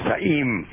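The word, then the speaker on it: Za'eem: Oh, Zaim. – Yes. – So, now, the word Zaim, when I asked you, is that...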